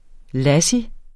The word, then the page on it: lassi: ˈlasi; ˈlɑsi